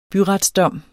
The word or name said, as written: byretsdom